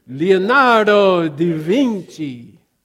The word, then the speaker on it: Leonardo da Vinci: We have to say it correctly. Yes, thank you. He is Italian. It is Leonardo da Vinci. So on 3, we will say it correctly. 1, 2, 3. Leonardo da Vinci. Da Vinci.